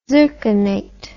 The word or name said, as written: zirconate